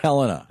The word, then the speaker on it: Helena: Or it's Helena, Montana. Now, how do you say it? – Helena.